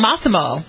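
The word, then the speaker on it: Mossimo: The manatee gray version of the dress in question, Mosimo Women's Plus Size. – Massimo. – Massimo, is that how you pronounce it? – Massimo.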